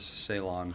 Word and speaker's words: Ceylon: It is Ceylon. – How do you spell it? Oh, C-E-Y-L-O-N?